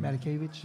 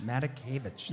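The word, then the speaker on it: Matakevich: Tyler Matakavich, how do you pronounce his last name? – Matakavich. – Matakavich, that's right.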